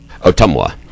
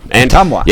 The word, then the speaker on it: Ottumwa: Ottumwa. – Ottumwa.